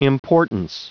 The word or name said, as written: importance